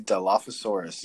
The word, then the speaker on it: Dilophosaurus: I think it's Dilophosaurus. – It is the Dilophosaurus, yes.